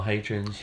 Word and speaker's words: Hadrian's: Went to Adrian's Wall, which, as you know, is my favourite wall. – Adrian, or Hadrian's. – Hadrian's. – Yeah. Just you know, correct pronunciation, that's all I asked for.